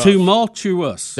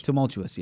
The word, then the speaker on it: tumultuous: Did I get close? – I'm just going to call it rough. – Tumultuous. – There it is. – Tumultuous, yes.